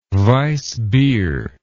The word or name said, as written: weissbeer